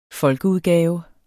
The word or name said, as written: folkeudgave